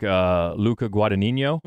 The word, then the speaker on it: Luca Guadagnino: I think Luca Guadagnino, mm-hmm.